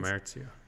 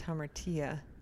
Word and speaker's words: hamartia: It's hamartia. – I think this is pronounced hamartia, though I have never heard it actually said. – I'm thinking of omertia. – Hamartia. Tia, yeah.